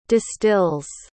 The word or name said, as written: distills